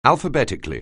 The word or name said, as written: alphabetically